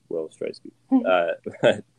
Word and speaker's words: Will Streissguth: And that's just my name, I think, which is Will Streissguth.